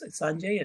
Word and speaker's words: Sanjay: Now I don't have to, you know, probably even saying my name would probably be Osanjay. Like in the 1990s when I was here, I would have to spell it out. Now it's Sanjay, and everybody, especially at the hospitals, everybody knows it from Sanjay Gupta.